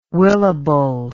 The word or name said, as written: willable